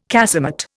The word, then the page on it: casemate: Indian